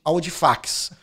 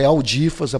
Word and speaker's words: Audifax: eu até passava um slogan que todo mundo só me chamava de Audifax. né? Mas é Audifas a pronúncia, mas escreve Audifax.